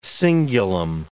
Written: cingulum

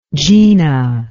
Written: gina

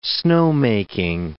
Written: snowmaking

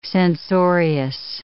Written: censorious

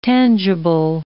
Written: tangible

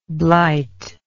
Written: blightingly

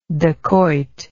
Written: dakoit

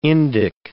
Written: Indic

